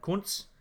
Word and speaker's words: Kuntz: Now, if you are familiar with the German way of speaking, you would actually pronounce his name Kunz.